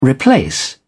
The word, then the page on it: replace: British English